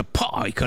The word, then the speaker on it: Pike: Nel 1871 Albert Pike, o Pike, ma si credo si pronuncia Pike all'inglese, la I si legge Aia, è generale, sovrano, grande commendatore del rito scozzese della massoneria che hanno i gembriolini, no?